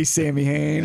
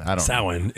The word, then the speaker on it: Samhain: They call it Samhain is, I guess, the technical pronunciation. – Yeah. Irish.